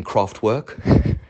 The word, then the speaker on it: Kraftwerk: Why am I pronouncing it like that rather than Kraftwerk? Because that's how they pronounce it. They're a German band. Kraftwerk.